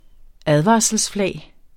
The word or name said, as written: advarselsflag